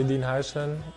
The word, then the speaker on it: Dean Huijsen: Os hablo del central Dean Huijsen. Bueno, no sé si lo digo bien. A ver cómo lo dice él mismo. – Soy Dean Huijsen, Dean Huijsen. – Más o menos, más o menos.